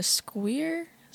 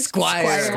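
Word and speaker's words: Squier: A squeer? Is that what it's Squire. – Called? – Squire. – That's yeah. Like, Squire.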